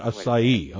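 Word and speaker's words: açaí: Well, for those of you wondering how you pronounce A-C-A-I, it is acai, right?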